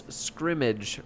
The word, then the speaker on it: scrimmage: Scrimmage, S-C-R-I-M-M-A-G-E. – Oh. – So it's kind of like... – Yeah, but I'm incorrect. – It's kind of like hammock, where we pronounce it different than the actual vowel that's there.